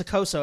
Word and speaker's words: Okposo: I think it's Akoso. I think the P is the silent. P is silent.